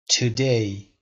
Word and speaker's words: today: today جا استرس رو تو قسمت دوم بذارید